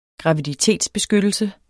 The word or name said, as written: graviditetsbeskyttelse